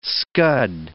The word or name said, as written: scud